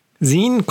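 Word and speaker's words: zine: Es ist quasi, Sehen kommt, um erstmal auf die Aussprache hinzukommen äh, oder darüber zu sprechen, Sehen kommt von Magazine.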